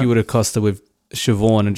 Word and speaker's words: Siobhan: Is that how you pronounce that? – Siobhan. – Yeah, Siobhan. – Oh, I didn't know that. – Yeah. – That BH sound. It's like in Welsh, a double D sound. Like if you've got two Ds together, it's actually a V. Like it makes a V sound.